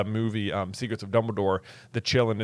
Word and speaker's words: Qilin: Or the Chillin. – Or the Chillin. – Yeah, which is spelled Q-I-L-I-N. – Yeah. – The Chillin, that's like the little horse dragon thing that can tell whether or not you're pure of heart.